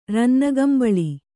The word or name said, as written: ರನ್ನಗಂಬಳಿ